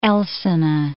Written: Elsene

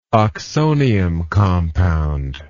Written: oxonium compound

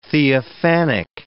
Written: theophanic

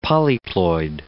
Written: polyploid

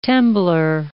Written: temblor

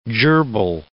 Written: gerbille

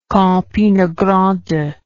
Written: campinagrande

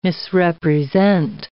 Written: misrepresent

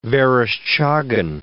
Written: Vereshchagin

